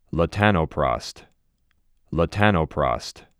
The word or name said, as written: Latanoprost